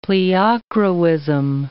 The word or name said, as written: pleochroism